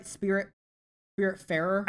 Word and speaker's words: Spiritfarer: Am I saying that right? Spiritfarer? – I think so.